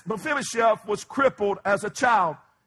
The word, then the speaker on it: Mephibosheth: Everybody say Mephibosheth. Let's say it together. Mephibosheth.